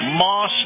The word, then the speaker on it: Maus: This portion of the program, my conversation with Colonel Jim Warshuk, is brought to you by Moss Nissan. Now, Moss is spelled M-A-U-S, Nissan, and the new 2021 Rogues are in at Moss Nissan, and they've made some really incredible upgrades to this fantastic SUV.